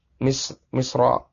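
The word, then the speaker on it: Misra (مصر): Tapi kalau dia menunjukkan sesuatu yang khusus, menunjukkan negeri Mesir, maka tidak ditanwin. Ini yang lebih kuat. Wallahu ta'ala a'lam. Jadi bacanya lebih tepat Misra, Hinda, Da'da.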